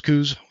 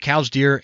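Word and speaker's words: Coues deer: He's involved with all sorts of things, deer management, mule deer, cows deer, however you want to say it, cows, coos. Jim pronounces it cows deer. And since he's the expert on deer, I guess I'm going to pronounce it cows deer.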